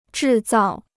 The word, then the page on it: 制造: zhì zào